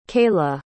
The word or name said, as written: kayla